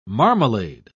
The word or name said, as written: marmalade